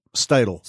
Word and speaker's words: Steidel: We're going to have folks there from M-City, from American Center for Mobility, Kurt Strudel. Is that how I'm pronouncing his name? Strudel? – Steidel. – Steidel. Thank you.